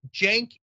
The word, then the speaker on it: Cenk: It's Cenk Uger. The C is pronounced like a J.